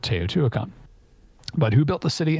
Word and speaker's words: Teotihuacan: Teotihuacan. Yes, never mind. I've heard this pronounced before. Teotihuacan.